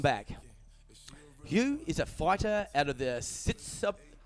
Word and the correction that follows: Sitsuthon: How do you pronounce that? Hugh Sitsup. It's a tongue twister. – I'm not going to try that one either. – Sitsuthon. Sitsuthon, yep.